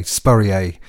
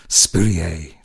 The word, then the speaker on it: Spurrier: I always sound like a lot of people pronounce my name that way, Spurrier. Spurrier. Sounds very French, doesn't it, Trevor? I sound windswept and interesting rather than very boringly English, Spurrier.